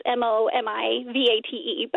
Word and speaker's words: Momivate: So the word, our nonprofit is called Motivate, but as with an M in the middle. So it's Momivate. But it is kind of from that inspirational moment. – And so I should pronounce it Momivate, right? – Well, I guess that might help people spell it.